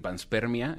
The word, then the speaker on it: panspermia: Yo recomiendo que tal cual busquen panspermia. En YouTube hay muchos videos. ¿Cómo se escribe? – Panspermia. – Ajá. ¿Panspermia o Vans? – Pans, eh, como de pants, como de pantalón, spermia.